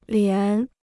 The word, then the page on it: 连: lián